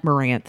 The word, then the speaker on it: Morant: They have the player at Ja, and I'm going to mispronounce his Morant. – Name. Morant. Thank you.